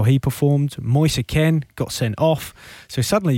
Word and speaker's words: Moise Kean: It's Moise Kean. It's very easy. Moise and Kean. David Moise, Roy Kean.